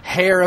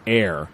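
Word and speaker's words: Heir: This is episode 13 of season 2, Hair of the Demon. – No, it's not. It's not hair. I believe it's pronounced air.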